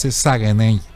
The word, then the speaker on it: Saguenay: Así no se pronuncia. Le Saguenay. Le Saguenay. Saguenay.